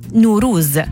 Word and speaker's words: نوروز: النيروز لفظة فارسية معربة، وتعني أول يوم من أيام السنة الشمسية عند الفرس، وتلفظ بالفارسية نوروز، وهي جمع لكلمتي نو وتعني جديد، وروز وتعني يوم، ليصبح المعنى يوم جديد.